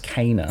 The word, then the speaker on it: Kena: And you pronounced it wrong. It's actually pronounced Kana. – Oh, so fucking sorry. The game I didn't play. – Yeah, so I play Kana. – Kena.